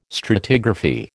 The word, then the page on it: stratigraphy: American English